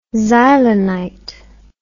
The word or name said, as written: xylonite